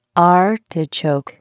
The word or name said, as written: artichoke